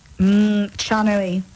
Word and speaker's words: Chanui: Chanui. – Okay. – Am I allowed to put brands out there? I don't know. – Yeah. – Rhymes with Hanui.